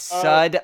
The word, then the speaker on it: Sud: It's sud like soap.